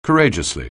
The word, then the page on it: courageously: anglais